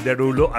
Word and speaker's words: Derulo: Por eso es el Derulo, así como francés. Se escribe Desroleaux, pero es Derulo, ¿ok?